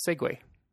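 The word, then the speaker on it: segue: en grej till en annan när man pratar kommer från franskan och stavas S-E-G-U-E. -E. Mm. Och uttalas då? likadant, segway. Ja, det uttalas likadant.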